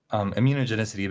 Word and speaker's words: immunogenicity: So immunogenicity basically is sort of the... – Wait, slow down. Teach me how to say it. – Immunogenicity.